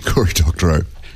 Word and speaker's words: Doctorow: Cory Doctorow, is that how I pronounce it? – Cory Doctorow. – Doctorow. Oh. 7 p.m.